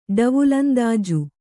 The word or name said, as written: ಡವುಲಂದಾಜು